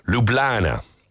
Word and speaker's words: Ljubljana: It looks very complicated, but all you need to do is drop the J, so it's pronounced Ljubljana. You can really find everything on Google.